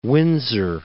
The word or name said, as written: Windsor